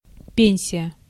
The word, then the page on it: пенсия: /ˈpʲenʲsʲɪjə/; pensija